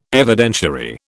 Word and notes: Evidentiary: British English

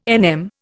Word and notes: NM: Indian

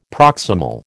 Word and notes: proximal: American English

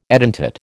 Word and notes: edentate: American English

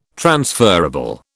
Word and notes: transferable: British English

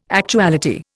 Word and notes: actuality: Indian

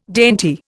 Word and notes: dainty: Indian

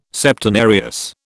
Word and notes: Septenarius: British English

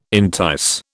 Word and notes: entice: British English

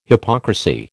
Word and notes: hypocrisy: American English

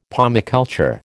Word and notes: pomiculture: American English

